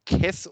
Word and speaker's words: caisse: Ähm, und nach einer, nach einer anderen Deutung kommt die Redensart aus dem französischen Caisse, für Kiste, das ungefähr Käs oder Käs ausgesprochen wird und dann zu Käse eingedeutscht wurde.